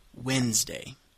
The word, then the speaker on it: Wednesday: Si vous regardez l'orthographe du mot Wednesday, c'est un peu étrange, je sais bien, mais on le prononce Wednesday. Comme il n'y a pas de D là-dedans.